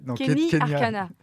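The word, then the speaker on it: Keny Arcana: Kenya Arcana, c'est ça ? – Non. Kenny Arcana.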